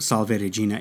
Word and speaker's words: Salve Regina: So the Salve Regina, which is pronounced Salve Regina in ecclesiastical Latin, means Hail Queen.